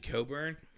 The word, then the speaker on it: Cockburn: It's actually Coburn, is how you say it, but it's spelled Cockburn.